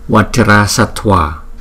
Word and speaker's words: Vajrasattva: Vajrasattva, vajrasattva, không, chữ t và chữ v đi cùng với nhau chúng ta tờ vờ thua thua sattva vajrasattva vajrasattva, không cha cha cha.